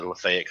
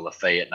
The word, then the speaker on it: Lafayette: were the Lafayette Commodores. Down here in Mississippi, they call it Lafayette, not Lafayette, like they do in most other places.